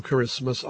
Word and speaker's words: Kiritimati: But wait, there is more from Kirismas Island, spelled as if it were pronounced Kiritimati. Via Conexión Digital,